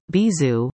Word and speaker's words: Bizou: Introducing Bizou, pronounced Bizou, which is the French word for kisses.